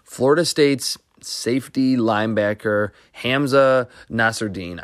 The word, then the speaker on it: Nasirildeen: And this prospect from Florida State, and it looks like his name is pronounced Nasirildeen. Looked it up last night. It's Nasirildeen. He said it in an interview, apparently.